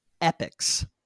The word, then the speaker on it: Epyx: They get in touch with a video game company called Epyx. Now, this is spelled really weirdly. It's E-P-Y-X, Epyx, right? – Epyx, very 90s name.